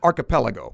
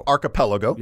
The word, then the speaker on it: Archipelago: How do you say that? Gulag? – Archipelago. – Archipelago. – Yeah.